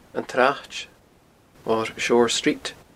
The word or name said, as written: antsraid